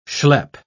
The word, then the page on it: shlep: British English